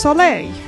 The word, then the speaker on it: Soleil: as I'm just told in the IRC by, when it was you and Retrix, I believe, it is pronounced Soleil.